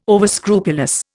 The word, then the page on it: overscrupulous: Indian